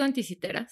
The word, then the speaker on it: Anticítera: ¿lo, pronuncio bien? – ¿Es Anticítera o Anticítera? – Yo le digo Anticitera, sí.